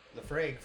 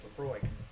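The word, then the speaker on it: Laphroaig: I mean, we call it Lefraig forever, and it's Lefroig.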